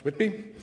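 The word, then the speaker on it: WITB: He is the father of the Working Income Tax Benefit, the acronym for which is helpfully pronounced WITBY, which helps Canadians over the so-called welfare wall.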